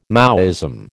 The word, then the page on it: Maoism: American English